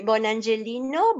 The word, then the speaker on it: Bonangelino: Eh, Bonangelino, Bonangelino se escribe, pero yo lo digo en, italiano, que es la patria de mis abuelos. Bonangelino, guión bajo, Gloria.